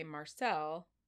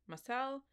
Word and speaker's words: Marcel: So this guy's name is, in English we would say Marcel, but since he is French, it is Marcel Petiot. Okay? Got it? – That was really bad. – Can you say Marcel Petiot? – Marcel Petiot.